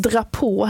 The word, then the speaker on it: drapeau: Och vissa, som drapå här till exempel, det stavas ju D-R-A-P-E-A-U. – Mm. – Och det låter ju inte riktigt som det stavas, utan dra på, då pratar vi flagga.